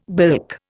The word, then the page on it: bilk: Indian